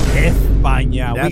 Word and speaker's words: España: España. – Ganaron. – Wow. – Okay. It's not just España, it's España.